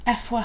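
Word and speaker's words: Afua: So we're also seeing Effwa Hirsch, which I only know that that's how you say it because of her episode on Love Stories.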